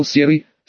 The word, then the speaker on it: Серый: Чжувон тогда помолчал, а потом выдал: «Серый, с ударением на втором слоге,